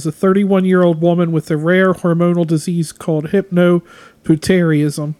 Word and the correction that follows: hypopituitarism: It's hypo. Hypopituitarism.